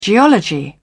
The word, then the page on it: geology: British English